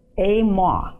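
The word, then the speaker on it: emah: When you look up all the words for the word fear, this particular word is pronounced amah. It's E-M-A-H.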